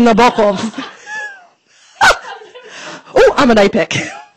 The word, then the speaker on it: Nabokov: Actually, it's Nabokov. I don't know if you... You've got to put the emphasis on the second syllable.